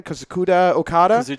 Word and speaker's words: Kazuchika Okada: Kazuchika Okada? – Kazuchika Okada is the main event.